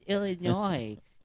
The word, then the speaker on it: Illinois: Like, that's not how you say it. It's Illinois. The S is silent.